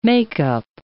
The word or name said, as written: make-up